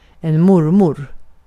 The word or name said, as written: mormor